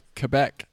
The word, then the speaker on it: Quebec: But it said that Quebec is pronounced Quebec.